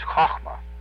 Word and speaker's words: Chochmah: Sephira number 2, I'm going to tell you what it sounds like, then I'm going to spell it for you, is Chochmah. That's a hard or a guttural CH sound. The sound Ch is like Loch, the Scotch Loch, you know, like lake. It's like Nacht in German. Ch, Chochmah. C-H-O-C-H-M-A.